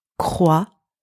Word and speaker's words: croissant: And finally, very important to remember, the letter T in that word is silent. So pretend it is invisible. Now, if we are putting all of these sounds together, we have croissant.